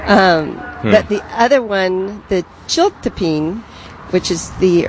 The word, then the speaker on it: chiltepin: Yeah, it's pronounced Chiltepin, but it's C-H-I-L-T-E-P-I-N.